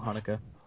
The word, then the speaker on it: Haneke: Another horror film that in the past decade, I guess, that commented on the violent nature of horror films by I think we disagree on how to say his name. Michael Haneke or Michael Haneke? – Michael Haneke. – Michael Haneke. Yeah.